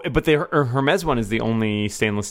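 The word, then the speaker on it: Hermès: So this Hermes, is that how it's always been pronounced, or did they change it today? Because I always thought it was Hermes. – No, it was always Hermes.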